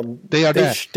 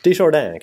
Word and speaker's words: Desjardins: Ja, Desjardins. Desjardins kan man säga. – Alltså, om franska eller amerikansk uttal.